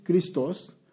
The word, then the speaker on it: Christos: The Greek word is Christos, and we just, in English, we just say Christ.